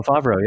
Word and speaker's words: Favreau: Yeah, Reese Witherspoon, and then I can't pronounce his name, but it's the guy who directed like Iron Man, Jon Favreau or something. – Oh, Favreau.